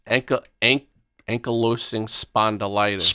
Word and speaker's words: ankylosing spondylitis: Ankylosing spondyitis. What is it? – Ankylosing spondylitis. – Spondylitis. Thank you.